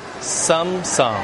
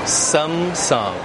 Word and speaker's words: Samsung: 那我们常用的这个手机品牌呢，叫做 Samsung, Samsung, Samsung, Samsung.